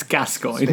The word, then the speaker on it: Gascoigne: No, um, we speak how people's names are pronounced, and wow. it's Gascoigne.